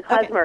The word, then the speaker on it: klezmer: Yeah, am I even saying it right? – You're saying it perfectly, klezmer.